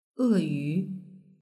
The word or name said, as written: eyu